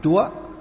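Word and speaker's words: Chúa (主): Là Chúa. Chứ chủ ở đây còn đọc là Chúa.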